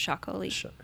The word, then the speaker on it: Txakoli: But it's pronounced Chacolí.